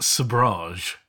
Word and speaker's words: sabrage: I think it's pronounced sabrage. – Oh. – But I would have pronounced it sabrage.